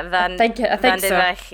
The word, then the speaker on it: Vandenreich: They spent ages trying to teach me how to say Vandenreich. Did I say it right? – I think so, yeah. – Vandenreich. So. – Yeah, it's the hard at the end.